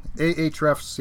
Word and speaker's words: Ahrefs: What about, there's another one here, I think Ahrefs, is that how you pronounce it? – Ahrefs, yeah,